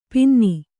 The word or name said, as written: ಪಿನ್ನಿ